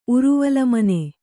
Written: ಉರುವಲಮನೆ

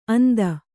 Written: ಅಂತೆ

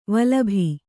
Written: ವಲ್ಲಭೆ

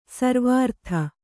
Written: ಸರ್ವಾರ್ಥ

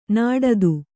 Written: ನಾಡಿದು

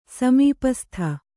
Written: ಸಮೀಪಸ್ಥ

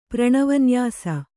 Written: ಪ್ರಣವನ್ಯಾಸ

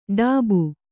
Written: ಟಬ್ಬು

